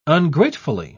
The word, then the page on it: ungratefully: British English